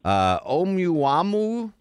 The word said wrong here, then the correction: Oumuamua: Oumuamua. – Oumuamua.